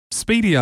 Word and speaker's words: SPDY: It's pronounced Speedy, I think, S-P-D-Y, and it's kind of like an enhanced version of HTTP.